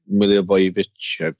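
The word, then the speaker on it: Milivojević: Milivojevic, that's how you pronounce his name, from Palace.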